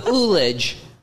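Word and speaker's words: ullage: Oolage. – Oh, God. Oh. – It's the oolage. – It's spelled with a U? I thought it was an H.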